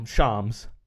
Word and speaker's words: Shams: Shams. Shams bomb. Sorry, yeah. shams. It rhymes with bombs.